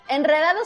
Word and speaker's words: enredado: Enredado, sin embargo, no, porque después de una N, esa R sí se pronuncia como fuerte. Entonces, enredado, Enrique...